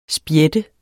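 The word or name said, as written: spjæt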